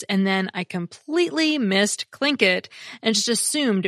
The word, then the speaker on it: Tlingit: Thanks to both Ben and Cynthia, who pointed out that the Alaskan native people are not the Tlingit. It's pronounced Tlingit.